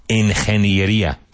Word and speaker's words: ingeniería: Ingeniería.